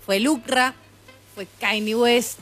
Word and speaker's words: Kanye: Aparte, Kanye se pronuncia como se escribe, boludo. Kanye.